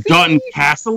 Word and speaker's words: Dalton Castle: How do you pronounce that? Castle? – I'm just saying Dalton Castle. – There you go. Dalton Castle, which here says is Dalton Castle and Big E fused together.